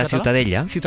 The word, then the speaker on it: Ciutadella: ¿Cómo se pronuncia? – La Ciutadella. – Ciutadella. Bueno, pues Ciudadela también en castellano.